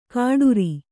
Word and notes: ಕಾಡುರಿ: kāḍuri; Kannada

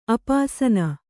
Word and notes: ಅಪಾಸನ: apāsana

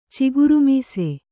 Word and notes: ಚಿಗುರುಮೀಸೆ: kannada

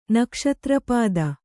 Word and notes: ನಕ್ಷತ್ರಪಾದ: kannada

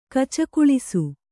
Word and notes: ಗಚ್ಚಗೊಳಿಸು: gaccagoḷisu

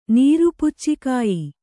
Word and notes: ನೀರುಪುಚ್ಚಿಕಾಯಿ: Kannada

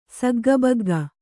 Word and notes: ಸಗ್ಗಬಗ್ಗ: Kannada

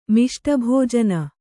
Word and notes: ಮಿಷ್ಟಭೋಜನ: Kannada

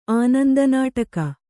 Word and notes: ಆನಂದನಾಟಕ: kannada

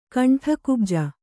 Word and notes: ಕಂಠಕುಬ್ಜ: kaṇṭhakubja

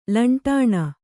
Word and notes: ಲಂಟಾಣ: laṇṭāṇa

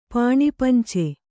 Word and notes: ಪಾಣಿಪಂಚೆ: kannada

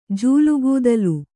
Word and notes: ಜೂಲುಗೂದಲು: Kannada

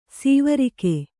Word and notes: ಸೈವೆರಗು: saiveragu

